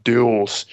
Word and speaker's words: Dulles: The agency's actions follow the March 16 arrest of Bo Xiang, a Chinese citizen, at Dulles Airport in, okay, Dulles Airport. Gotta get my American pronunciation there.